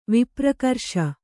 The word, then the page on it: ವಿಪ್ರಕರ್ಷ: vipra karṣa